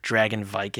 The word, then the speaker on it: Dragonwyck: Vincent Price's first leading role was in a movie called Dragonwyck, or Dragonvike, if you want to pronounce it correctly.